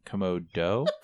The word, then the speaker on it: Komodo: Kimono. – The Kimono Gila monsters. – I believe that's pronounced Komodo.